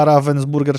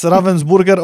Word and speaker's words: Ravensburger: że ja Ravensburger często wymawiałem jako Ravensburg? Okej, okay, ja nie wiedziałem, jaka firma robi Puerto Rico w ogóle. – Więc Ravensburger organizuje mistrzostwa w Puerto Rico.